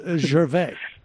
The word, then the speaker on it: Gervais: I don't know, but it's Gervais.